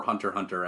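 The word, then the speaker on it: Hunter × Hunter: And then Hunter x Hunter, or Hunter Hunter, I think is the actual pronounced term for it.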